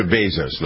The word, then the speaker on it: Bezos: Thought it was interesting that Jeff Bezos, you pronounce it Bezos or Bezos, ladies? – It's Bezos.